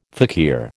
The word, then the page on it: fakir: American English